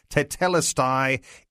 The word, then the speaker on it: Tetelestai: And we have discovered that we have the pronunciation pretty right, but you still need to practice it. Tetelestai, it is finished.